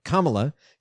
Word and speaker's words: Kamala: If Crazy Joe cannot serve his full term, Kamala, I gotta say her name right, Kamala, cannot by constitutional law become president.